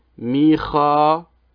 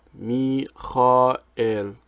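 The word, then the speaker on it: ميخائيل (מיכאל): ثانيا، كلمة ميكائيل أو كما قال هو ميكان ونقل الصيغة العربية القرآنية، أصلها في الكتاب المقدس ميكائيل، أكتب هكذا، تنطق ميخائيل، الكاء هنا تنطق احتكاكية ولا تنطق انفجارية، تتحول من كاء إلى خاء، ميخائيل، وليست هي بمعنى مثل الله، لأن مثل الله هذا نصف الكلمة.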